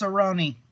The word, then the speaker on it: Cerrone: Cerrone. It's pronounced Cerrone.